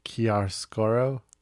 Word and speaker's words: chiaroscuro: I know what Chicascaro means. Did I pronounce that right? – No.